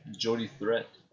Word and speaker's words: Jody Threat: Elle a gagné son match contre Jody Tree, si je le dis bien. – Jody Threat.